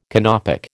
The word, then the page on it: Canopic: American English